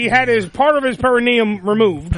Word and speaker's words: perineum: By the way, perineum? – Yeah, is the technical term.